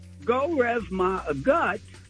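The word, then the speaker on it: go raibh maith agat: C-R-E-A. – Okay, Pastor. – Now, go rev ma a gut is spelled G-O, rev, which isn't English, alphabetacion or alphabet, is R-A-I-B-H, and then ma is M-A-I-T-H, and a gut is A-G-A-T.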